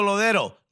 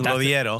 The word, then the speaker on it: Lodeiro: Nico Lodero looks Lodiero. Right? Apparently we've been saying it wrong. Lodiero.